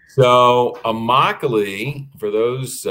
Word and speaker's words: Immokalee: So it's Immokalee. That is a word from the Seminole Indian language, and it means my home. You can always tell the name or pronounce the name of an Indian word because every letter is pronounced. Nice. Every letter is articulated. So there's nothing that remains silent.